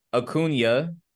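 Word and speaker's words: Acuña: No, you're right, because it's A. I know the name, so you're right. It's A-C-U-N-A. It's that common Latin name.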